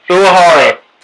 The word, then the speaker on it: Uehara: Uehara.